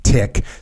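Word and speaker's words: TIC: A tick, pronounced tick, stands for troops in contact.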